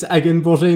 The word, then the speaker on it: Hagenborger: Yeah, pretty well. It's Hagenborger in French, but anyway, I mean, pronounce it as you like in English. I guess everything works.